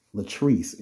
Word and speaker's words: LaTrice: LaTrice, and that's spelled L-A-T-R-I-C-E.